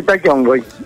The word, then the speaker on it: Taki Ongoy: Especial de Taki Ongoy, es así, se pronuncia así, ¿no? Está bien. – Exactamente, sí, Taki Ongoy.